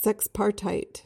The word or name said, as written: sexpartite